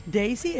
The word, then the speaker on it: Daisy: No, se lee, no. En inglés. – Daisy. – Daisy es la pronunciación de ese nombre.